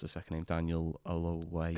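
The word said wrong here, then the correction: Kaluuya: Kalula. – I just got Daniel it way wrong. Daniel Kalula. That was shocking. – Oh my God. Yeah. Daniel Kalula.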